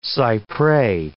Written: cy pres

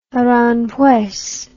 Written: Aranjuez

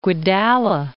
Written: Guedalla

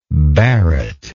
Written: barret

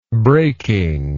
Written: breaking

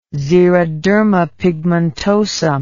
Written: xeroderma pigmentosum

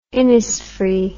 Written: Innisfree

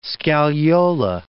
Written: scagliola

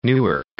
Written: Newark

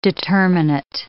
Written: determinate

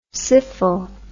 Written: syphil

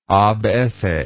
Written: abesse

